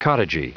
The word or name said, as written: cottagey